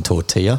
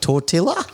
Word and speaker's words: tortilla: Taylor Swift makes music for people who pronounce the L's in tortilla.